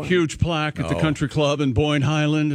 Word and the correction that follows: Boyne Highlands: First of all, it's pronounced Boyne Highlands.